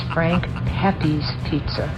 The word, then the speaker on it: Frank Pepe's Pizza: You're having pizza from Frank Pepe's, not Pepe's. – Frank Pepe's! – It is not French, it's Italian. So it's Frank Pepe's Pizza.